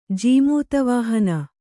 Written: ಜೀಮೂತವಾಹನ

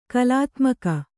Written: ಕಲಾತ್ಮಕ

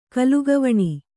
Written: ಕಲುಗವಣಿ

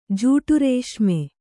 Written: ಜೂಟುರೇಷ್ಮೆ

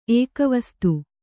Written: ಏಕವಸ್ತು

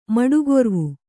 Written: ಮಡುಗೊರ್ವು

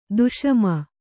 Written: ದಶಮಿ

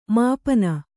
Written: ಮಾಪನ

